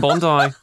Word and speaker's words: Bondi: Like, they'll go to Bondi and they'll be like, they say it's beachside, but it's quite a big walk from Bondi.